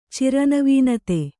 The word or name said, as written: ಚಿರನವೀನತೆ